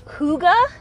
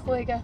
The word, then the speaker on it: Hygge: And it's called Hygge. H-Y-G-G-E. – That is not how I would say Hygge. It's like a Hygge.